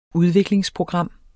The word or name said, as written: udviklingsprogram